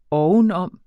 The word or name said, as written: ovenom